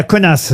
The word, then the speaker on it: Kaunas: Il était né à Kaunas, vous devez connaître.